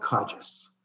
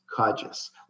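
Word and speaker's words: Kodzis: Yeah, it's Bob Kodges. Kodges. Like Hodges, but with a K. – Okay. – Even though it's spelled K-O-D-Z-I-S, I've learned to answer to pretty much anything.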